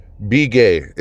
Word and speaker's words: Begay: Begay is exactly how I said it. It's spelled B-E-G-A-Y. Begay.